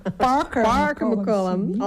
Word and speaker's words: Parker McCollum: Nou, dan gaan we nu naar Parker McCollum. – Parker McCollum. – Oh. – Zo spreek je dat uit.